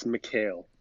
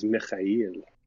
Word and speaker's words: McHale: Well, the white way of saying it is McHale, but so that's like how we've always said it. But the proper pronunciation, if you want to know, is Mikhail.